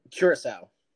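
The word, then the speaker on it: Curaçao: It's spelled like Krakako or whatever, but I thought it sounds like the name of an island. – Yeah, it does. It's Curacao though.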